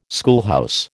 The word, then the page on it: schoolhouse: American English